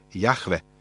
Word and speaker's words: Jahve: Gospod je na jevrejskom tetragram, koji je transkribovan kao Jehvh, a na našem jeziku se izgovara kao Jahve ili Jehova.